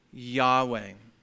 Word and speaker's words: Yahweh: Yahweh. That's usually how it's pronounced in English. It's actually just four letters, YHWH. And we usually pronounce it Yahweh.